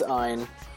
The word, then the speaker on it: Ayn: We can't tell you to do that for obvious reasons, but then Ayn Rand would not be getting any money in her estate, which is useful. – Is it Ayn or Ayn? – It's Ayn. It's Ayn.